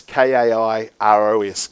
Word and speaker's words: Kairos: It's it's not actually as in Kai, and they go, "Who's the Ross?" But if you want to know how to spell it, it's K A I R O S Kairos.